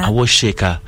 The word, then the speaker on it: Awosika: Awosheka. – Awosheka. – Shika. Okay. Okay. So the S turns like Asha. – S-I, but it's pronounced sh, right? – Yeah.